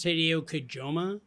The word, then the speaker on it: Hideo Kojima: I believe it's pronounced Hideo Kojima.